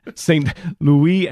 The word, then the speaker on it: Louis: Saint Louis, if I could just say that French pronunciation, at least get close to it. Saint Louis and Zélie Martin,